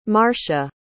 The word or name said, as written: marcia